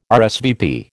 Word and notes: RSVP: American English

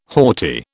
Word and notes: haughty: British English

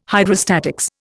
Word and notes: Hydrostatics: Indian

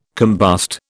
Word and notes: combust: British English

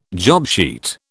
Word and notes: jobsheet: British English

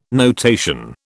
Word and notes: notation: British English

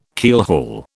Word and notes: keelhaul: British English

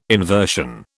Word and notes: inversion: British English

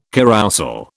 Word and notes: carrousel: British English

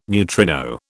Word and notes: neutrino: British English

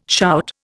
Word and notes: shout: Indian

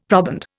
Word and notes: proband: Indian

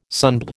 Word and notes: sunbed: American English

